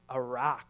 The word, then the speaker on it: arak: arach. That's the Hebrew, arach. It looks like a rake, A-R-A-K, the phonetic way to pronounce it.